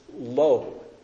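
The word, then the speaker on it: lo (לא): They're both pronounced lo, like lo and behold, except that it's not the same lo, okay? But it's the word lo.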